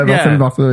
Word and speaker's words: Marcel Marceau: What are you, Marcel Marceau? – Marcel Marceau. – Did I say it right? – Yeah, Marcel Marceau, yeah.